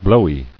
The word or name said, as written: Blowy